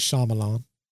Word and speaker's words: Shyamalan: Night Shyamalan. Did I say his name right? M. Night. – I'm surprisingly, Shyamalan? I think Shyamalan. You did. No one gets that right the first time. Bam. He wrote and produced, and I believe even had a cameo in the movie The Village.